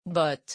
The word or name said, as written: bottes